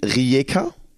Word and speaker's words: Rijeka: Du hast damals in Rijeka, habe ich das richtig ausgesprochen? – Sehr gut.